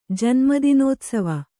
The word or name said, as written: ಜನ್ಮದಿನೋತ್ಸವ